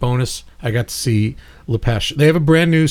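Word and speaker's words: Lapêche: Lepesh is spelled L-A-P-E-C-H-E, like the peach in French.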